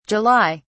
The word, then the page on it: July: British English